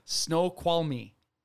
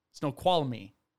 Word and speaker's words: Snoqualmie: Snoqualmie. – Snoqualmie. – Snoqualmie National Forest. It's spelled Snoqualmie. I had to search a lot of different videos till I heard someone say it. So I hope they're correct.